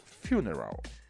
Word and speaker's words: Funeral: Funeral, pronúncia correta.